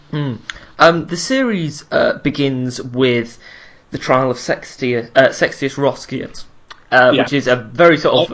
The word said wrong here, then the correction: Sextus Roscius: I'm going to be fluffing the pronunciations all the way. Sextus Roscius.